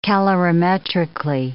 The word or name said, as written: calorimetrically